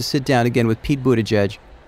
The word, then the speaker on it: Buttigieg: And while we were preparing for the interview, the hardest question my producers tackled was how to pronounce his name. But in the months since, we've all learned to say Pete Buttigieg. Buttigieg.